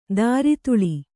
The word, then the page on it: ತಿರುದಳ: tiru daḷa